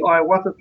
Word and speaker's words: ayahuasca: Well, a good example here is, as you were saying, you've done the ayahuasca thing.